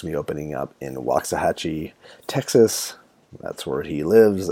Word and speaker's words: Waxahachie: Yeah, Waxahachie.